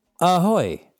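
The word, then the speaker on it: Ahoj: Ahoy. I'm doing well. Ahoy is how you say hello in Czech. – Yeah, ahoy.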